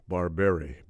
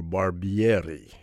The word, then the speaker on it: Barbieri: Argentinian-born Leandro Barberi, better known as Gato Barberi, and or Barbieri, if you're going to pronounce it with the proper pronunciation.